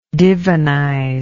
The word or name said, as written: divinization